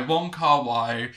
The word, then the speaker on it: Wong Kar Wai: Or it's a Hong Kong made set film by Wong Kar Wai.